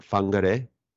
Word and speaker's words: Whangarei: And I believe is it Whangarei? – Yeah, absolutely. So it's a Maori place name.